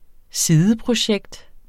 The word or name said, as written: sideprojekt